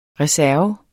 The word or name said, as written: reserve